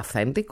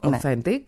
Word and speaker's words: authentic: Απλά προφέρεται, όχι authentic, authentic.